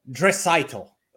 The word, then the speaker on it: Draisaitl: Draisaitl.